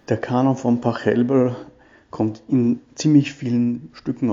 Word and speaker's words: Pachelbel: Pachelbel. Betonung auf Pach.